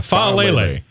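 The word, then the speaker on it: Faalele: Yeah, Faolele. Yeah, that's how you say it, Faolele.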